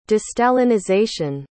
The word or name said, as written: de-stalinization